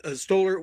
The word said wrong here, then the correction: Stiller: was was so nice. Did I get the name right? – Stiller, yeah. Ben Stiller, sure. – Stiller, I'm sorry.